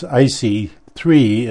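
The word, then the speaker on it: ISEE-3: They changed the name, interestingly enough, because it's IC-3 is the way it sounds, but it's spelt ISWE-3, and the 3 being a 1, like a Roman numeral 3.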